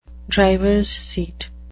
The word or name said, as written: drivers seat